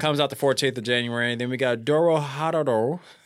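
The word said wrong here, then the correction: Dorohedoro: It says Doro He Doro. – Doro He Doro.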